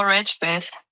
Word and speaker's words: Ragebait: Ich habe gerade angedeutet das Thema, und jetzt sag nochmal, wie wird das ausgesprochen? Ragebait, ist das korrekt? – Genau, Ragebait.